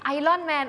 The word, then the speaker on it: Iron Man: Iron Man ออกเสียงว่า Iron Man Iron มันจะเป็น Iron มันจะไม่มีตัว R อยู่ในนั้นแต่ว่ามันจะมีนิดนึงนิดนึง Iron Man Iron Man มันไม่ Iron Iron Man